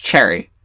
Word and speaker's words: Cheery: And it's like, we have, is it in this book where they say, like, Cherry's name is C-H-E-E-R-Y, but it's pronounced Cherry.